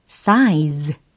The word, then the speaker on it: size: 영어로는 size 이렇게 발음하시면 되겠죠. size size 이렇게 끝부분에 z 이렇게 활용하시면 됩니다. size, size. 네.